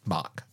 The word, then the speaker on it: Bach: Have you heard of Bach's flower remedies? – No, I have not. – Bach is in like Bach, the composer, Bach, but not that guy. Just spelled the same.